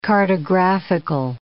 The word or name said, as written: cartographical